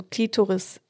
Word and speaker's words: Klitoris: Also mit der Betonung auf dem I, also Klitoris, oder auf dem O wie Klitoris? – Ja, also die erste Variante, also Klitoris, das ist die, so würde man es aussprechen.